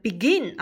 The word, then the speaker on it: begin: 那咱们拿这个 begin 啊来做例子，你看 begin 那个重音就是在那个 gin 的音节上，所以它既满足重读，又满足我们所说的后面这三个字母是属于辅元辅的一个结构，所以 begin 要变成现在分词要双写 n 加 ing。